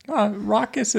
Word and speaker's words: raucous: Raucous is an O. – Raucous. – Not ruckus with ruckus, a U.